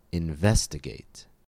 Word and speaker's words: investigate: In investigate, it falls on the second syllable, investigate. And in underestimate, it falls on the third syllable, underestimate.